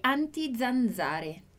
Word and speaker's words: anti-zanzare: La nostra prima frase, a nossa primeira frase diz: Io ho lo spray anti-zanzare. Anti-zanzare. Prestou atenção aqui na minha pronúncia do Z? Dillo chiaro: anti-zanzare. Anti-zanzare. Bom, essa é uma pronúncia que a gente tem da letra Z no italiano.